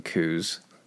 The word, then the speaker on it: coup: I want to pronounce the P in coup so bad.